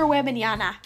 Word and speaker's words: Wembanyama: But on the cover of it, it shows Victor Weber. Oh, I don't even know how to say his name. It's weird. – I don't either. It's like Victor Wembanyama.